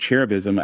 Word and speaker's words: cherubism: So our word is cherubism.